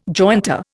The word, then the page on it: jointer: Indian